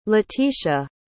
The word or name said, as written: latitia